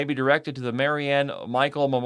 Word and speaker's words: Michel: Michael, that last name is spelled M-I-C-H-E-L, of Hills. Marianne A. Michael, age 85, died peacefully Thursday, January 5th, 2023 at Atrium Village in Hills.